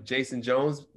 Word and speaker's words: Jayson Jones: I am Jayson Jones.